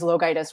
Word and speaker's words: LoGiudice: My last name is LoGiudice, which no one can pronounce.